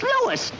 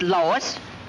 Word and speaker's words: Louis: Me name is Lewis, not Lois. Gee whiz, everybody in Toy Palace me name wrong. It's Lewis. L-O-U-I-S.